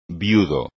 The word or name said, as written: viudo